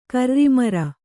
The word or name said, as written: ಕರ್ರಿಮರ